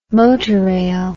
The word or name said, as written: motorail